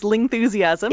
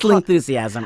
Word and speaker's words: Lingthusiasm: It is, you got it right the first time. It is Lingthusiasm. – It is Lingthusiasm.